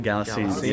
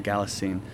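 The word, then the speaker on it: Kalasin: Galassine. – Yeah. It's spelled with a K, but you pronounce it like a G. Galassine.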